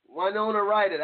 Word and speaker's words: Winona Ryder: Winona Ryder.